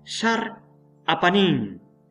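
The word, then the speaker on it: Sar Apanin (שר הפנים): la pronunciación de príncipe de la presencia es Sar Apanin.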